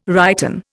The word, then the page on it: righten: Indian